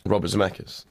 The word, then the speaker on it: Robert Zemeckis: Robert Zemeckis.